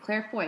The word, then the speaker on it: Claire Foy: Claire Foy? – Yeah.